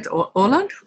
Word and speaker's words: Åland: Well, you know, you and I are collaborating, and you're in, is it Åland? Is that how I pronounce it in Swedish? – Yeah, that's right. Åland, or Åland Islands.